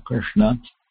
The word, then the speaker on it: Krishna: Let me write Shri Krishna Chaitanya on the board because there's a S in the throat, Shri, and retroflex S in Krishna, Krishna.